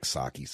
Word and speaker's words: Psaki: I like Psaki, so I just can't. I have to pronounce the P.